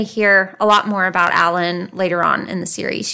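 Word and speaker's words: Allyn: However, 2 years after Ed was born, his youngest sister, I think her name is pronounced Allen. It's A-L-L-Y-N.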